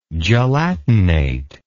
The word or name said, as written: gelatinate